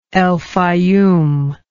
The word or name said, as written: El Faiyum